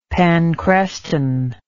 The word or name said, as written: panchreston